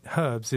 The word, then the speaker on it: herbs: But the old way that English people used to say herbs is how Americans say it.